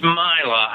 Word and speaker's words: Maila: It's Myla. I've actually heard it pronounced both. I had to spend some time trying to figure this out myself, but it seems like she says Myla.